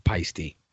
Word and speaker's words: Paiste: By the way, just for the drummers that are listening to this, it is fucking pronounced Peisty. Just FYI. If you don't believe me, go to their website. They have an actual pronunciation on the website.